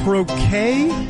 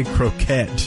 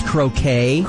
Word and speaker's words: croquet: Is it croquet? That's the proper way to say that, right? I feel like I've heard some people say croquette. – But don't act like you don't know that it's croquet.